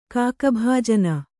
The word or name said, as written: ಕಾಕಭಾಜನ